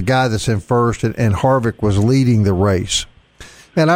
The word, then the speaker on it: Harvick: If I mispronounce his name correct, but Kevin Harvick, is that not right? Like the main guy right now? – That's right.